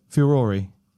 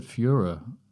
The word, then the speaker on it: furore: Furore. Yeah. – I've always said Führer. – No, Führer is what Adolf Hitler was. – Right. I thought they were pronounced the same.